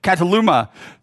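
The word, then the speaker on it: kataluma: The word that is used in this text, in Luke chapter 2, verse 7, is actually, Cataluma. I was pronouncing it wrong earlier and Ryan corrected me. Cataluma.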